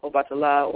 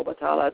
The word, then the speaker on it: Obatala: And, you know, as a peacemaker, because that was his work, he was a priest of Obatala or Obatala, depending on which tradition you come from in terms of how you pronounce it, Oshallah.